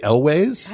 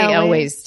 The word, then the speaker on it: Elwes: So Cary Elwes, is that how you say his name? – I say Elwes. – I say Elwes too, but Cary Elwes wrote a whole book about making The Princess Bride called As You Wish.